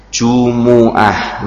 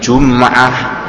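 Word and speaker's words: Jumu'ah (جُمُعَة): Itu bacanya terkadang sebagian imam disukun miyaumil jum'ah. Ustaz ya, itu salah. Jadi menurut beliau Ustadzuna dalam masalah qiraat ya, itu tidak ada yang baca atau yang dibaca jum'ah. Bacanya apa? Jumu'ah. Satu lagi dalam surat Al-Kafirun, sisan.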